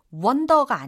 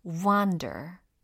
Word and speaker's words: wander: 발음은 wonder가 아니라 wander. wander. 그래요.